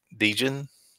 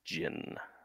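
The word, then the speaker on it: Djinn: Next up, is it Dijin? Is that how you'd say that? – I think the D is silent. I think it's just Jin.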